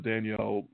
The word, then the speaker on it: Danielle: I wanted to give props to Danielle Hunter of the Minnesota Vikings, which is, I believe his name is pronounced Daniel, but it's spelled Danielle, so I call him Danielle.